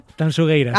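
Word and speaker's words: Tanxugueiras: A ver, repítemelo, ¿cómo tengo que pronunciarlo? – Tanchugueiras. – Sí, esto me lo corrige Fermina, Agustín, mucho a Marta, por favor. Digo, mira que yo siempre pronuncio. A ver, otra vez, dímelo. – Tanchugueiras. – Acaba de deshacerse la manifestación a las puertas de la Gran Vía, de lo bien que lo has dicho.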